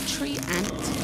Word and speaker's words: treant: Did you say tree ant? – Yeah, tree ant. It's spelled T-R-E-A-N-T.